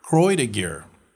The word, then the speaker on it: Croix de Guerre: I used to call it Croix de Guerre, but it's actually Croix de Guerre.